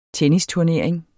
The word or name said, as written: tennisturnering